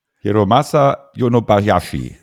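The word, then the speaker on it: Hiromasa Yonebayashi: Hiramasu Yonebayashi.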